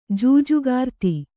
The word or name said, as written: ಜೂಜುಗಾರ್ತಿ